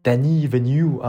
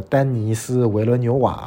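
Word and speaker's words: Denis Villeneuve: 丹尼维纽啊，这个我用他非常标准的英文发音来来读啊，这个丹尼斯维伦纽瓦啊，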